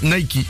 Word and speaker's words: Nike: Et d'ailleurs, en fait, il n'y a qu'en France où on dit Nike. Dans tout le monde, on dit Nike. – Ouais. – Parce qu'en anglais, le E se prononce I. – Et ouais. – Donc en fait c'est Nike, ça se prononce Nike.